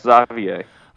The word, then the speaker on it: Xavier: You seem to have very much difficulty pronouncing Xavier. – No, that's how you pronounce Xavier.